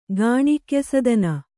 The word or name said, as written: ಗಾಣಿಕ್ಯಸದನ